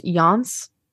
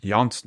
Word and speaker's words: Janis: I think it's pronounced Jans. Nords. – Jans Nords. – But like it's spelled J-A-N-I-S. – Yeah. – I did look it up and that's how it's pronounced.